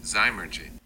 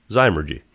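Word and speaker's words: zymurgy: I'm going to click to play it so we can get this, how it's pronounced. – Zymergy. – Zymergy.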